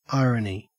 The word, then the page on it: irony: Australian English